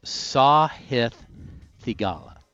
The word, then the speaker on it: Sahith Theegala: His name is pronounced Sahith Theegala.